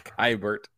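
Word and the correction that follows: Keibert: Oh, that last week I said Kybert. And I, we know Brad corrected me, K-Bear.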